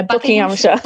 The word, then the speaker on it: Buckinghamshire: Yeah, Buckinghamshire.